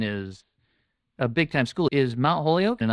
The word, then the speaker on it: Mount Holyoke: Am I pronouncing that right? – Mount Holyoke, yes.